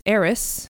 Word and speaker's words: Arras: I believe you pronounce it Arras, which is A-R-R-A-S, meaning a rich tapestry hung along the wall.